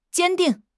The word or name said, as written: 坚定